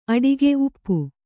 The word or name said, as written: ಅಡಿಕೆ